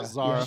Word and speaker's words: Zara: Like, I love that even though Zara, is that how they pronounce her name in this? – Yeah, Zara.